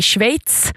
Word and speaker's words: Schweiz: Årets turnering spelas borta i Schweiz. Nu uttalade jag det rätt. Det är sjukt svårt. Jag lär mig aldrig hur man ska uttala Schweiz.